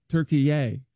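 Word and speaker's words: Türkiye: So he immediately demanded that it be pronounced Turkey-yay. – Turkey-yay. – T-U-R-K-I-Y-E.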